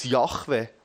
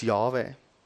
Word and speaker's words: Jahwe: Gewisse Indizien, die für mich am einleuchtendsten tönen, gehen davon aus, dass es als Jahwe ausgesprochen wird. Als Jahwe.